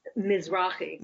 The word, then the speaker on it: Mizrahi: Mizrahi.